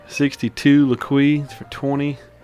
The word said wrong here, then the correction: Lalique: And a 65 Laqui for, how do you say it? – Lalique. Yeah.